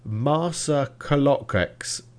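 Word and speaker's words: Marsaxlokk: Marsa Kolokex, and that is spelt MarsxLokk.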